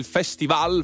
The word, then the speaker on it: festival: Lipa, Love Again, 20 e 20 minuti aspettando l'inizio della prima serata del festival, festival con l'accento, esatto, sulla A, voi mi confermate, sì, giusto?